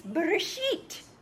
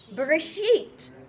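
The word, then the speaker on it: Bereshit (בראשית): So let's sound this out. So it's Bereshit. Bereshit. Okay, so now you're reading. Bereshit.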